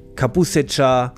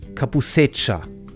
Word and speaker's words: Kabusecha: Gyokuro, Kabusecha, ich habe es falsch ausgesprochen. Gyokuro, Kabusecha, Sencha.